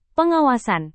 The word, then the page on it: pengawasan: indonesian